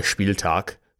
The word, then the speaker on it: Spieltag: Again, it was a home game, so that means a Spieltag, probably Spieltag cover, that's how you pronounce it, cover for the Flyer.